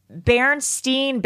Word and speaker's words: Berenstain: So, for example, there's the Berenstain versus Berenstain Bears controversy, that the Berenstain Bears, which is how most people remember it, it's actually the Berenstain Bears. – Insane. – Yeah. – I still think it's... – It's spelled S-T-A-I-N, yes. Not Steen.